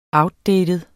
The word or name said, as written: outdated